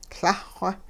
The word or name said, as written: Lah-xw